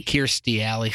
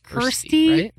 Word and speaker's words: Kirstie: Oh, Christie Alley. – Kirstie Alley. So close. – Kirstie Alley. – Kirstie? Right? – I think we all just pronounce it three different ways. What is it? – It's Kirstie.